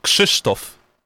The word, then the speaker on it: Krzysztof: Okay, now how do you say your name? – Krzysztof. – Krzysztof. – Yes, Krzysztof. – Krzysztof. – Krzysztof. Did I say it right? – Kinda. – Krzysztof. Oh, yeah. Krzysztof. – Need some polishing, but you're getting there.